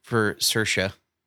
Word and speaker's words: Saoirse: Saoirse.